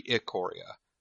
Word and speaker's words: Ikoria: So Tabak had a tweet today that the first syllable is IH. – That should be I then. So it should be Ikoria. So like Ikor.